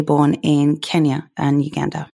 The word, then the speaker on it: Kenya: You know, I forget that my accent is becoming very, very strange living in America. Kenya, yes, Kenya. They're very keen over there for us to say it correctly.